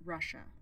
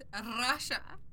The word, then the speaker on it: Russia: We're looking for an import of live cargo coming from Russia, perhaps. – She said that wrong. It's Russia.